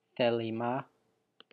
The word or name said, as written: thelima